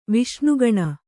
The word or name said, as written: ವಿಷ್ಣುಗಣ